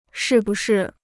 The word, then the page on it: 是不是: shì bù shì